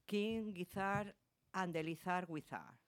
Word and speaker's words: King Gizzard and the Lizard Wizard: Vamos a poner una música de, a ver si lo digo bien, que parece un trabalenguas, del grupo King Gizzard and the Lizard Wizard.